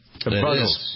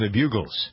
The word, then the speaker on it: Buggles: The Buggles. Yeah, I always they used to tease me. Well, I used to call them the Bugles.